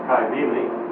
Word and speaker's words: Cybele: Sometimes spelled with a K, but either way, the better pronunciation is Cybele.